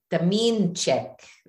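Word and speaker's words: Domińczyk: Demenchik. – She taught me how to say her last name. like Demenchik. Demenchik. And I always got it right from then on, which does not mean at all.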